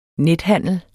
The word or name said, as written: nethandel